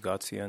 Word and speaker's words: Ghodsian: It's A-R-A-S-H, and last name is Gatsian, in case. – How do you spell it? – G-H-O-D-S-I-A-N.